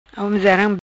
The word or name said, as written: Homezaram